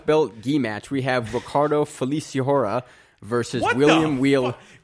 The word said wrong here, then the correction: Feliciano: Don't you dare question my mispronunciation of black belt when you say Feliciora. Where is there an H? – It's a silent H. – Ricardo Feliciano.